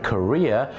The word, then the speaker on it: career: C A R E E R career ออกเสียงว่า career คล้ายๆกับประเทศเกาหลีเนี่ยนะครับอาชีพเนี่ยนะแต่บ้านเราเนี่ยนะครับไปออกเสียงว่า carrier เนี่ยนะครับ